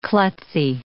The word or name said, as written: klutzy